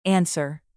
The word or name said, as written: answer